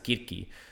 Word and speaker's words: Circe: I do know that there is actually there's a book that I read called Circe, or we would pronounce it Kirki,